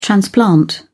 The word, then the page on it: transplant: British English